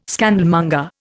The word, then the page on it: scandalmonger: Indian